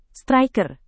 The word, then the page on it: striker: Indonesian